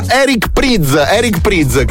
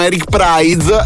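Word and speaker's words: Eric Prydz: Eric Priz, che tutti dicono Eric Prize, Eric Priz è un altro falso amico per quanto riguarda la pronuncia.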